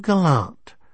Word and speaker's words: gallant: "Well, aren't you gallant?" – She put the stress on the last syllable.